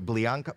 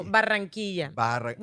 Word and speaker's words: Barranquilla: So you are from, how do you pronounce the part in Colombia? Blianca? – No, Barranquilla. With two R's.